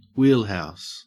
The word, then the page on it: wheelhouse: Australian English